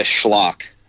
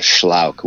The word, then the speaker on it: Schlauch: So I go by Schlock. There's a correct pronunciation of Schlauch, which is German, but my dad always went by Schlock, and so I just kind of ran with.